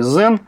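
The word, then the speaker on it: Xen: Ну, собственно, уж коль мы заговорили о Citrix и Zen, правильно произносить все-таки Zen.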